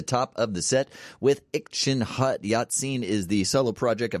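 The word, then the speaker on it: ircenrraat: And Ikchin Hut, spelled IrcenRaat, pronounced Ikchin Hut, are a part of Yupik folklore.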